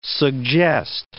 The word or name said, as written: suggest